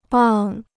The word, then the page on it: 棒: bàng